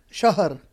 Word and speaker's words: Shahar: How do you pronounce that in Shahar. – Shahar.